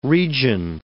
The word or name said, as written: region